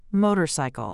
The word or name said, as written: motorcycle